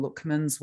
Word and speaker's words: Luckman: Sol Lookman, S-O-L. And his surname is Luckman, L-U-C-K-man, Luckman. I just pronounce it that way.